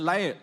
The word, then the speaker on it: lyre: and with timbrel and lyre. I always cannot pronounce this. How to pronounce that? Lyre. That's like lying, right?